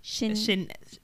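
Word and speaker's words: Shenseea: So speaking of Meg, how do you pronounce the Jamaican? Is it Shanisha? – Shinsia.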